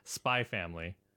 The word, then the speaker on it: Spy x Family: So yeah, Spy X Family. – You don't pronounce the X. It's just Spy sorry.